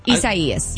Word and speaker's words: Isaias: Am I saying it right? – Yes. – I'm not doing Isaias? – Yes. Isaias. – Acai. What is you're saying like acai, like an acai bowl.